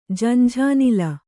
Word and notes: ಝಂಝಾನಿಲ: jhanjhānila

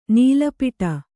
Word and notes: ನೀಲಪಟ: nīla paṭa

